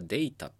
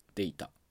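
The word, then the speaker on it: data: で、そこでデータっていう単語が出てきて、当然これはデータっていう風な発音ですよね。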